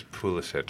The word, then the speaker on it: Pulisic: I think the word you're trying to say is Pulisic.